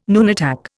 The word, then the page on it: nunatak: Indian